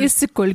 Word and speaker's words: Issyk-Kul: Issyk-Kul? – Issyk-Kul, genau.